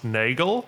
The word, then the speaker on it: Nagel: I think your surname is pronounced Nagel. – Yeah, nailed it. – Yes, because it's very close to Afrikaans Nagel.